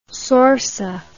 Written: sorsa

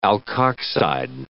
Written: alkoxide

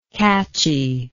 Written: catchiness

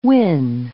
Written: wynn

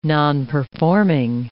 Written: nonperforming

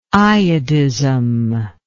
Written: iodism

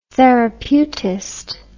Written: therapeutist

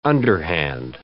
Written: underhand

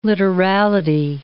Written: literality

